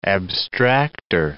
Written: abstracter

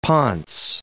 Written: ponce

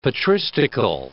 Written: patristical

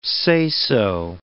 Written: say-so

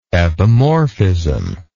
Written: epimorphism

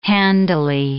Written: handily

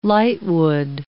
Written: lightwood

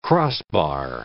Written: crossbar

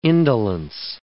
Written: indolence

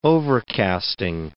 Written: overcasting

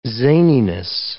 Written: zaniness

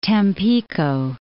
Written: Tampico

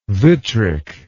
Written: vitric

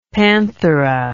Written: panthera

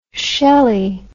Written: schelly

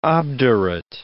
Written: obdurate

